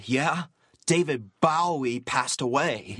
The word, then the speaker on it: Bowie: You're pronouncing his name wrong. It's Bowie, not Bowie. – In England, they pronounce Bowie, Bowie. – In Canada, they pronounce Bowie, Bowie. – I thought Canada was part of England. – Well, it's not. And even if it was, David Bowie, Bowie, is not from Canada.